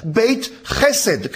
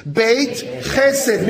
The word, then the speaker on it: Beit Chesed: Auf Hebräisch heißt er nicht Bethesda, sondern Beit Chesed. Können wir das alle mal aussprechen? Bitte. Beit Chesed, bitte. Beit Chesed.